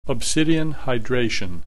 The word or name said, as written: obsidian hydration